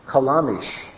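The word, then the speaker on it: kalamish (חלמיש): Now, the Hebrew word for flinty rock is the word kalamish. And it's spelled like this. Ket lamed mem yad shin. Kalamish.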